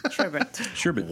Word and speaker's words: sherbet: One R in that word. We just watched a thing on, like, the sherbet. Words you think you were pronouncing correctly, but you're not, and that was one of them.